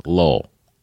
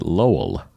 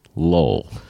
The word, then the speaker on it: Lowell: I'm from Lowell, which we pronounce Lowell. – Lowell? – Lowell. – Lowell. – So people who are not from there will pronounce it Lowell, and we pronounce it Lowell.